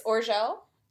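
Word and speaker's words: Orzeł: Oh, it's Orzel? – Yeah, they sent us like a video on YouTube of how to say the word. It means eagle in Polish, which makes sense because minus the Z, it's orel in Bulgarian.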